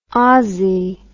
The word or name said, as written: ossie